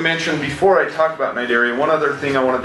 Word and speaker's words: Cnidaria: The phylum Cnidaria, and notice that the C is silent when you say the word. The phylum Cnidaria include organisms such as your coral, your jellyfish, and your Portuguese man-o-war.